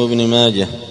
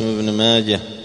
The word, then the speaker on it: ابن ماجه: قوله رحمه الله تعالى ولابن ماجه عن الطفيل أخي عائشة لأمها، ما اسم ابن ماجه؟ ما اسم ابن ماجه؟ محمد بن يزيد القزويني، وينطق بالهاء وليس بالتاء، ما تقول روى ابن ماجة، قل روى ابن ماجة، ولا تقول ولابن ماجة، ولابن ماجة عن الطفيل، قل ولابن ماجة عن الطفيل.